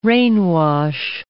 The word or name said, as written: rainwash